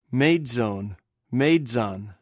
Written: meizwn

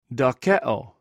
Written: dokew

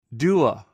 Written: duo

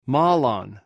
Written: mallon

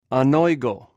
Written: anoigw

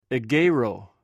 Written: egeirw

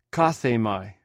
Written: kaqhma

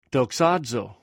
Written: doxazw

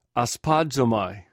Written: aspazo

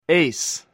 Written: eis

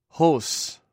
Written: hws

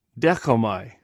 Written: decoma

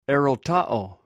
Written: erwtaw

